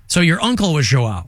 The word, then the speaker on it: João: J-O-A-O? – João. – There's a tilde over the A. João? Is it João? – João. – João Neto and Ken Weeks. – A lot of people will also say João, but João is how you say it in Portuguese.